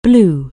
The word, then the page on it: blow: anglais